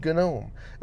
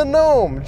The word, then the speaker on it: GNOME: GNOME. And man, I still freaking hate pronouncing that damn G. But it is the proper way to pronounce it, so I'm going to pronounce the damn G.